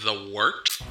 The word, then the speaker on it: wort: Hey, moron, it's pronounced the wort. – What the hell is a wort? – It's pronounced the wort. – What is it? The wort? Yeah, okay, I'll do that again. This is the wort on Govs Radio. – It is the wort, not fort, not short, the wort.